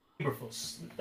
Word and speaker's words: Eberflus: I don't know if I said that right. – Eberflus. – Thank you.